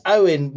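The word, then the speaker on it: Eoin: I know it's spelt a bit weird for your English eyes, but it's Owen Morgan.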